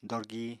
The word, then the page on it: dorgi: apatani